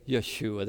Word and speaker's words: Yeshua: So we, when we praise Jesus, it's really saying Yeshua. That's the way the Hebrews would pronounce it.